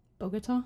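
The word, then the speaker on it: Bogotá: Bogota? That's how you say it.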